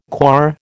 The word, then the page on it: coir: American English